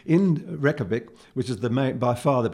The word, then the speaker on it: Reykjavik: The name was given, and it's R-E-E-K-A-V-I-C, it looks like Reykjavik, it's pronounced Reykjavik, Reykjavik, was given to the city by Nordic settlers around AD 870, and translated it means Bay of Smokes.